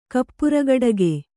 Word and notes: ಕಪ್ಪರಗೊಡಗೆ: Kannada

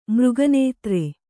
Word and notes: ಮೃಗನೇತ್ರ: mřga nētra

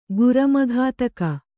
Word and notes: ಗುರಮಘಾತಕ: kannada

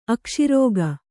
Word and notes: ಅಕಶೇರುಕ: akaśeruka

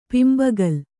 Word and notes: ಪಿಂಬಗಲ್: kannada

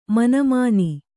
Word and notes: ಮಿನಿಮಿನಿ: kannada

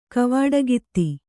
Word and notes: ಗವುಡಗಿತ್ತಿ: Kannada

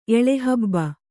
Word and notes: ಎಳೆಹಬ್ಬ: Kannada; eḷehabba

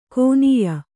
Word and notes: ಕೋನೀಯ: kōnīya

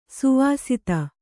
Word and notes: ಸ್ವಾಸ್ತಿ: Kannada

